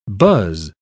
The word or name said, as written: buzz